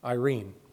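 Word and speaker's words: Irene (εἰρήνη): The Greek word is Irene. Irene, pronounced just like the name.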